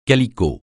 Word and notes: calicot: francais

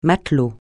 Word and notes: matelot: francais

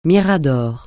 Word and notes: mirador: francais

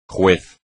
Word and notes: juez: Spanish

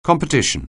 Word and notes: competition: anglais

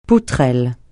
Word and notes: poutrelle: francais

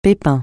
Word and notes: pépin: francais

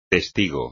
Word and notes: testigo: espagnol

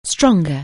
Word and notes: strong: anglais

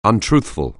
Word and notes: untruthful: anglais

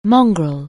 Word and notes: mongrel: anglais

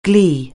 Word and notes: glee: anglais

